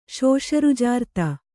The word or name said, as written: ಶೋಷರುಜಾರ್ತೆ